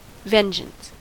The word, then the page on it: vengeance: American English